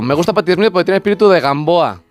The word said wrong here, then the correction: Rimbaud: Como se pronuncia Rimbaud, pero Rimbaud.